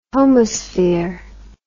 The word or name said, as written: homosphere